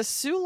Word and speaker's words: Sault: Yeah, the Sault Locks is generally spelled phonetically as S-O-O rather than S-A-U-L-T, like the cities.